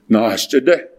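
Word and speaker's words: Naschitti: And so in Navajo, we say, it's pronounced like this, Nos Chiri. So that means badger coming out of hole.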